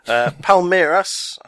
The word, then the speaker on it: Palmeiras: Palmeiras, I hope I've pronounced that right.